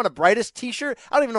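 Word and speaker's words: Briedis: It's Maris Bredis. So you got to pronounce both the I and the E in the last, Bredis, basically.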